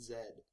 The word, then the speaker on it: Z: Because if it was English, it'd be Zed. It's American, it's Z. – It's Z. Zed is how they say it in English, huh? – Yes, in England and Canada.